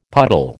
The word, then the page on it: puddle: American English